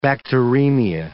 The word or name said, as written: bacteremia